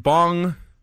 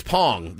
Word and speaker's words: Bong: Bong. What's it? Bong. – It's Pong. They pronounce it Pong.